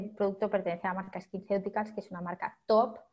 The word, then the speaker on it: SkinCeuticals: Escuché también un producto que mencionabas que se llama Blemish and Age Defense de SkinCeuticals, no sé si lo pronuncié bien.